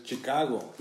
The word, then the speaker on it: Chicago: Bro, you're saying it with Mexican pronunciation. It's Chicago. – It's Chicago. – No, it's Chicago.